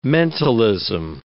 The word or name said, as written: mentalism